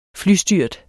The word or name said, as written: flystyrt